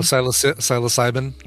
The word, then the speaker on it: psilocybin: psilocybin? – Oh, psilocybin.